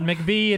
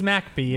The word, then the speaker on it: McBee: It's not McBee, it's MacBee.